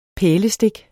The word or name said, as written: pælestik